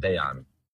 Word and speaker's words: Dejan: Und doch, man spricht auch das J aus, aber irgendwie verschluckt man es ein bisschen. Dejan.